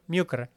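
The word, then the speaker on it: mjúkr: Se pronuncia en realidad mjukr.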